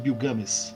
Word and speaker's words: Gilgamesh: Eu vou recomendar aqui a edição mais recente, publicada pela editora Autêntica, da Epopeia de Gilgamesh, que na verdade se pronuncia Gilgamesh, mas eu vou falar Gilgamesh, todo mundo vai conhecer como Gilgamesh.